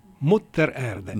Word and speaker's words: Mutter Erde: Is it Mutter Erde? Is that how you pronounce it? Mutter Erde. – Mutter Erde. – Mutter Erde. – Yes.